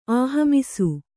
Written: ಆಹಮಿಸು

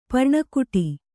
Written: ಪ್ರಾಣಕೊಡು

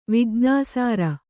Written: ವಿಜ್ಞಾಸಾರ

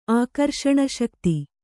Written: ಆಕರ್ಷಣಶಕ್ತಿ

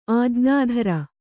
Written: ಆಜ್ಞಾಧರ

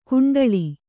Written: ಕಂಡಾಳು

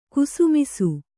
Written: ಗುಸುಮುಸಿ

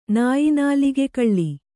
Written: ನಾಯಿನಾಲಿಗೆಕಳ್ಳಿ

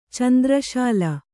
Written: ಚಂದ್ರಶಾಲೆ

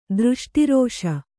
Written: ದೃಷ್ಟಿರೋಷ

